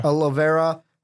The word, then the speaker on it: Olivera: The Braves get second baseman Hector Olivier. Am I saying that right? – Olivera.